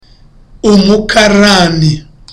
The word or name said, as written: umukarani